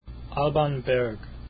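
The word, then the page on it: Alban Berg: ahl -bahn behrg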